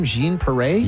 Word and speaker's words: Jean Paré: Jean Paré. Is that how you say her name? Jean Paré? – Yeah.